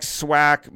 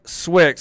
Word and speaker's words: Swick: So people would come up to me and they'd be like, oh man, you're my favorite fighter, Mike Swack, Mike Swook. Like they couldn't say my name. And the second Joe Rogan and Mike Goldberg said Quick Swick, since it rhymed, that was like what made people finally understand my last name.